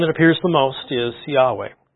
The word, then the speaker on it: Yahweh: Most recent scholarship and most people who really dig into the Hebrew language and try to reconstruct the pronunciation believe the correct pronunciation is Yahweh, just so you're aware of that.